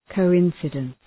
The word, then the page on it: coincidence: kəʋ’ınsıdəns